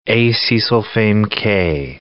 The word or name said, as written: acesulfame-K